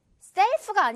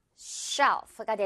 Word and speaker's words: shelf: self가 아니겠죠? self가 아니라 쉬이 발음 쉬 조용히의처럼 쉬로 시작하면서 shelf가 되는 거죠.